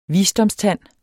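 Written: VISDOMSTÆNDERNES

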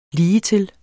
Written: ligetil

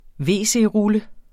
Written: wc-rulle

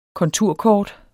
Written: konturkort

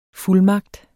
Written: fuldmagt

